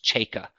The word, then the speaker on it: Cejka: He had earlier, he sought assistance from a Maine State Police trooper named Robert Cheka, and C-E-J-K-A, it's pronounced Cheka, who Cheka is in uniform, and he's driving a marked police cruiser.